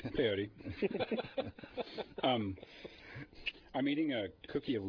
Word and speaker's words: peyote: And it's hard to, if you're not actually chewing peyote, it's hard to actually express those. Did I say that right? Peyote.